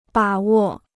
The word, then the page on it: 把握: bǎ wò